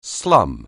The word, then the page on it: slum: anglais